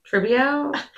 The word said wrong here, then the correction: Trevizo: I was editing the video, and I was like, that's not how you say her name, Katie. – What is it? – I think it's Alexi Treviso. – Treviso. Okay. – I kept saying Trevisio.